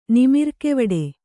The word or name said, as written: ನಿಮಿರ್ಕೆವಡೆ